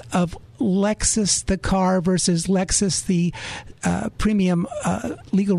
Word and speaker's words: Lexis: And uh, they're spelled differently, but they're pronounced exactly the same. So Lexis, the you know, database.